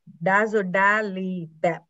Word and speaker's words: dazodalibep: oh so it's called dazodalibep oh, I got it right.